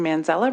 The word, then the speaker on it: Manzella: I am Senator Teresa Manzella, representing Senate District 44, and for the record, my last name is spelled M-A-N-Z-E-L-L-A.